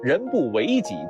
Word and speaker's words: 人不为己: 这句话应该念作人不为己，天诛地灭，人不做自己才是天诛地灭的事儿。